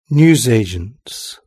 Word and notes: newsagent: British English

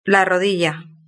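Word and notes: la rodilla: Spanish (Spain)

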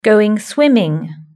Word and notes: going swimming: British English

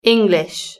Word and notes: english: British English

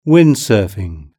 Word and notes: windsurfing: British English

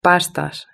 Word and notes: pastas: Spain Spanish

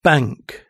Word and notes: bank: British English

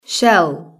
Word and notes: shell: British English

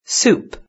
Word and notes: soup: British English

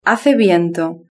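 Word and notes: hace viento: Spanish (Spain)

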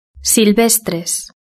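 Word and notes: silvestres: European Spanish

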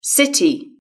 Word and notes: city: British English

